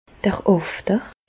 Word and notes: d'r Àfter: Bas Rhin; Reichshoffen

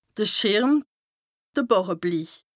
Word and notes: de Schìrm, de Bàràbli: Bas-Rhin; Strasbourg

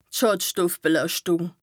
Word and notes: Schàdstoffbelàschtùng: Bas Rhin

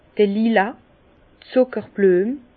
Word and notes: d'r Lila, Zùckerblüem: Bas Rhin; Herrlisheim

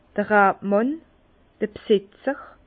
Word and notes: d'r Rabmànn: Bas Rhin; Herrlisheim

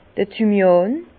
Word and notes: d'r Thymian: Bas Rhin; Herrlisheim

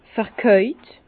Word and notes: vekäut: Bas-Rhin; Herrlisheim

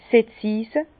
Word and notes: 's Setzise: Bas-Rhin; Herrlisheim

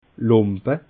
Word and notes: lumpe: Bas Rhin; Schiltigheim

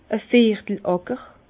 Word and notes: a viertel Àcker: Bas-Rhin; Herrlisheim

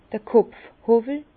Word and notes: d'r Kopfhowwel: Bas-Rhin; Herrlisheim

